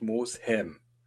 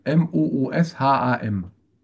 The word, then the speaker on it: Mosham: Weil ich weiß jetzt nicht, wer es im Hörspiel ist, aber einer sagt wirklich ganz deutlich Mosham. Wie mit Ä. Und Mosheim heißt es, glaube ich, sogar auf der Hülle. – Es heißt Mosham steht auf dem Schild drauf. Mooshaam. – Dann spricht sich das bestimmt Mosham.